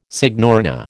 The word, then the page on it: signorina: American English